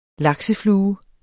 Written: lakseflue

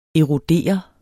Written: erodere

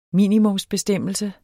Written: minimumsbestemmelse

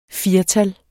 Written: firtal

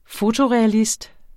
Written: fotorealist

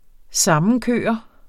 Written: sammenkørt

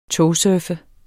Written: togsurfe

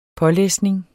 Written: pålæsning

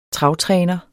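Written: travtræner

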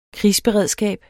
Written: krigsberedskab

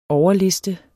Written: overliste

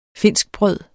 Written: finskbrød

